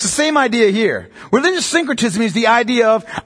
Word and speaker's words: syncretism: The second thing is syncretism. What that means is it's the creation of new philosophies and new religions. Syncretism. S-Y-N-C-R-E-T-I-S-M.